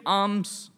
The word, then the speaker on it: alms: You give alms to the poor, A-L-M-S, alms, not arms. Have I pronounced it correctly?